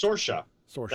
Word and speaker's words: Saoirse: Saoirse. That's actually how it's pronounced. – It's like Saoirse. – Yeah. Saoirse.